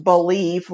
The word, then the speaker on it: Bleav: But I will tell you that we joined a new network called Believe, B-L-E-A-V, but it's pronounced Believe, like you believe and I believe and we believe.